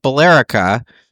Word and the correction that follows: Billerica: Nope. – No. – Try again. What? No, I won't even make you do that. Billerica. – Billerica. Wow. I thought Minnesota had some bad pronunciation. – No, we like to mangle things up here as well. – That's Billerica. Fantastic.